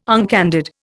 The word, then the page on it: uncandid: Indian